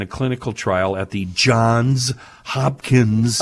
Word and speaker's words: Johns Hopkins: Thank you for saying it correctly. – Center for Psychedelic and Consciousness Research. I could do an hour on why we have to call it Johns Hopkins.